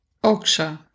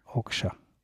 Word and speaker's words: Okja: Okja. – Okja.